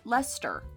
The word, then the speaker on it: Leicester: It's pronounced Leicester. Leicester. – Like Leicester. Mo Leicester? – Yes, exactly like that.